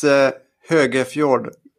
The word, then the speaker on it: Hogefjord: And it's Hogefjord. That's kind of hard to pronounce, perhaps.